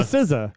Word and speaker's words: SZA: Sia, how do you say SZA? I'm pronouncing like she spells it like the RZA. – Oh, yeah, so she's the SZA. – Yeah, so I'm just going to say it's the SZA.